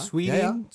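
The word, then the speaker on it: Swedien: Also das war wohl Bruce Sweden. – Spricht man den so aus? Swe ja. Sweden? Ja, ja. – Sweden. Nee. – Ja, war richtig.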